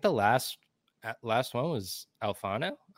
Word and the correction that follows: Alfaro: Alfonso, right? – Alfaro. – Alfaro. I just butchered that so Come on, bad.